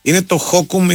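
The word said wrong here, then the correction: Hokum: ή Χοκούμ ή κάτι τέτοιο. – Χόκαμ. Ναι. – Χόκαμ, συγγνώμη.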